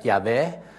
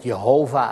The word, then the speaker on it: Yahweh: Maar dat woordje Heer, dat is Yahweh, J-H-W-H, hè, we weten niet hoe we dat moeten uitspreken. Soms, meestal spreken we het uit met Yahweh, maar je kunt het ook met Jehovah uitspreken.